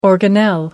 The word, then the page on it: organelle: American English